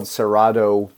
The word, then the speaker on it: Cerrado: Cerrado, it's spelled Cerrado, but in the Brazilian Portuguese we say Cerrado, is a vast tropical savanna in the north-northeast of Brazil.